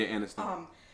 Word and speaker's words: Aniston: Jennifer Aniston. – Mm-hmm. – Am I saying her name right? – Yeah, Aniston.